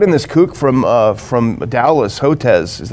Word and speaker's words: Hotez: And they brought in outside experts, including the notorious Dr. Peter Hotez, right? Remember him? Is that how you pronounce it? – Yeah, but what about, did Fauci get invited? – No, he was there.